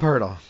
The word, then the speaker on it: Poeltl: This game could get ugly, so it could be Poeltl time. – Yeah, interesting way to pronounce his name. It's basically turtle with a P. – Yeah, Jakob Poeltl.